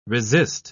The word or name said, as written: resist